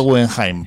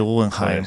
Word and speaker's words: Guggenheim: ¿Te has visto cómo yo pronuncio yo Guggenheim? Eso, ¿te has dado cuenta, no? – Sí, perfecto alemán. – Pareces de Porto. – No, es que todo el mundo lo dice en inglés.